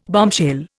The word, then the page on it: bombshell: Indian